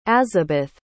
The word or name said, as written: azabeth